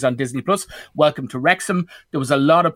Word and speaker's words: Wrexham: The streaming, which is Disney Plus this week, and it's Welcome to Wrexham. Am I pronouncing that right? – That's right, yes. Yeah.